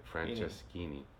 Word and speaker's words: Franceschini: Yeah, it's like Francesco with Ini on the end of it, Franceschini. Yeah. Without the O and Ini.